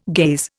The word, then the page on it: gaze: Indian